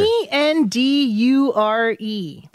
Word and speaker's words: endure: 근데 요 D 발음이 뒤에 있는 유 발음하고 붙잖아요. – Uh-huh. – 그러면 j 소리가 나요. u 아. 그래서 endure oh, yeah. 라고 하는 사람들도 있어요.